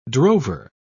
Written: drover

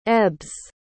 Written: ebbs

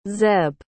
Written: zeb

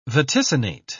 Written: vaticinate

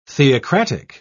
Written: theocratic

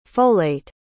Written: folate

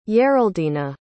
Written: yeraldina

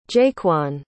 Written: jaquan

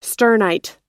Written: sternite

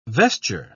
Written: vesture

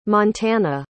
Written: montana

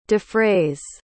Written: defrays

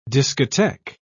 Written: discotheque

